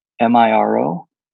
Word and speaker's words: Miro: Me, spell it. M I R O. Am I? Oh, Miro.